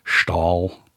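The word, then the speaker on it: Staël: That's that 1958 biography of the woman whose name I can't pronounce, Madame de Stael, or Stahl. Stahl.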